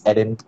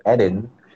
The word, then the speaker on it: Eden: Wait, where does the pronunciation of Eden come from, if you don't mind me asking? Because to me, it spelled like Eden, so I would pronounce it as Eden. So, yeah. – So, I mean, my parents named me Eden. – Yeah. – And they said it's the Garden of Eden.